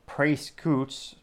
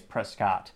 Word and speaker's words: Prescott: Prescoots. Ha, kidding. The internet told me it's Prescott in terms of pronunciation. And his profile says Louisiana Pride, Mississippi Grind Cowboy Living Mom, M-O-M, what?